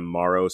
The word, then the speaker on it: Morrow: Yeah, you can follow me on Instagram or TikTok at Martin M. Morrow, spelled like tomorrow.